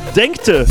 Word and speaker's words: Denkte: Und der Yannick schreibt, Sven aus Denken. Habe ich das falsch ausgesprochen? Nee, Denkte. Groß Denkte.